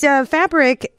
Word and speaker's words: FBRK: You know, it's Fabric, which is spelled FBRK.